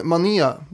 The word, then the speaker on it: Manet: Men Manet, det är ju rätt uttalat i alla fall.